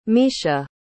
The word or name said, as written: mischa